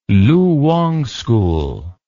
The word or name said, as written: Lu Wang School